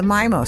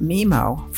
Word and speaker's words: MiMo: He pioneered the MIMO style, often pronounced MIMO. For the record, it is pronounced MIMO. M-I-M-O is an acronym for Miami and Modern.